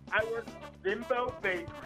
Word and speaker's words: Bimbo: It's pronounced Bimbo, but it's Bimbo.